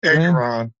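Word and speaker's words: Engoron: Judge Arthur Egg Moron. I'm not pronouncing his name right. – Engeron. Engeron, I think. – That's pretty close.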